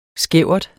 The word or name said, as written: skævert